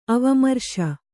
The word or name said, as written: ಅವಮರ್ಶ